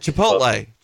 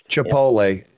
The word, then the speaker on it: Chipotle: Did I say that right, guys? I always get the wrong. No, it's saying, Chipotle. – Chipotle. Yeah.